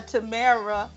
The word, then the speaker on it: Tamara: And Tamara, some people call her Tamara, rhymes with Scara.